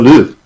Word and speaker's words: Louvre: The Louvre? How do you say it? – The Louvre.